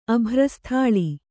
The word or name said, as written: ಅಭ್ರಸ್ಥಾಳಿ